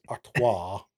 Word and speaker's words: Artois: You do have to say Stella Artois. It's pronounced Artois.